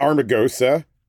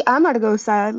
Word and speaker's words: Amargosa: Would you say Armagosa or Armagosa or? – I would say Amargosa, like the R is a little bit later in the phrase.